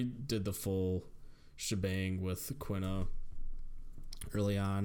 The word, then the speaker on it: Quina: Quina. How do you pronounce it again? – Quina, there you go.